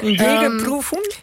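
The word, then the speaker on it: Jägerprüfung: Wat normaal gesproken, volgens mij heet het een Jägerproefung. Zeg ik dat goed, Sjoerd? – Ja, ja. – Jägerproefung?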